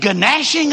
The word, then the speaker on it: gnashing: How many of those parables about judgment end with something like, and they will go to where there is weeping and gnashing of teeth. I know the G is silent, but I just like to say it.